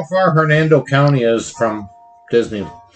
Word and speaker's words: Hernando: Did I say that right? – Maybe. Hernandez? Hernando or there's Hernandez? What? – Hernando. H-E-R-N-A-N-D-O. – Yeah, you said it right. – O-U-S-E.